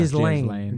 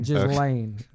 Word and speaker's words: Ghislaine: I don't actually know how to pronounce her name. I just call her Ghislaine. Ghislaine. Ghislaine is what the sophisticated people say.